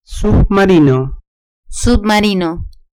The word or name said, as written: submarino